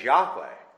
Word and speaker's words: Yahweh: His name is Yahweh. And that's the correct understanding and the correct pronunciation of it.